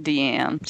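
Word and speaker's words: Diane: Yeah, that's how she spells it, Deanne Arbus, but they pronounce it Deanne.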